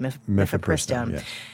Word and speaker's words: Mifepristone: is it Mifepristone. Thank you. – Mifepristone.